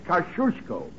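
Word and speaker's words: Kościuszko: In Poland, we say his name Tadashish Kosciuszko. But in America, Tadashish Kosciuszko is fine. – Jenny, please. – Thank you, young lady. Tadashish Kosciuszko. How's that? – Very good. You say it like you're going to sneeze, and you got it.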